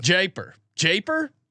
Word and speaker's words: Jaipur: How do you pronounce it? – That's what I would have said. – Jaipur. – You got it.